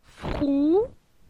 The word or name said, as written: Frou